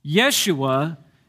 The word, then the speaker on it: Yeshua: Yeshua is the Hebrew pronunciation of Jesus.